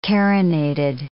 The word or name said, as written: carinated